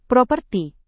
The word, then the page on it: properti: Indonesian